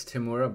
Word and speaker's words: Temuera: Similar excitement was met once Temuera Morrison. Did I say that right? – I believe it's Temuera, but I don't know.